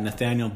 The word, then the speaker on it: Nathanael: I'm on Instagram at Nathanael Butler Official. My first name is spelled NathanaeL. Remember, it's A, not I.